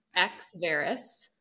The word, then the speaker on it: Exverus: So your first question before we went live was, how do you pronounce it? And it's, you did it correctly, which is amazing. Exverus.